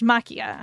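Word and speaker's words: Maquia: So let's talk about, we watched Makia when, or excuse me, it's pronounced Makia.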